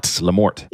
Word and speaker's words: Lamorte: I thought your name looked French, Lamorte, but I've seen enough of your videos now. I know it's not Lamort. – Yeah, it's Lamort. It gets mispronounced a lot. I guess it go either way.